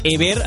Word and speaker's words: Ever: Ever Pena, ho repetirem mil vegades perquè a nosaltres encara ens costa també, però avui ahir ens informaven des del club que no és Ever, és Ever, aquesta pronunciació del nom del jugador gallec sense accent, i que avui, Sergi Parc, què tal, com estàs?